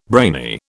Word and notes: brainy: British English

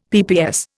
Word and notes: PPS: Indian

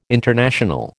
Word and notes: international: American English

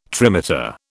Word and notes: trimeter: British English

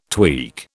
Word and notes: tweak: British English

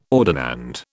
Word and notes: ordinand: British English

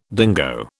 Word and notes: dingo: British English

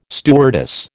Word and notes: stewardess: American English

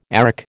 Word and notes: arrack: American English

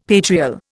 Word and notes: patrial: Indian

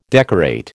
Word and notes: decorate: American English

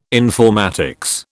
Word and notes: informatics: British English

